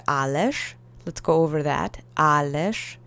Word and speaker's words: ależ: Ależ is spelled with the z at the end, but again, with speech, you sometimes soften syllables. So let's say ależ together three times.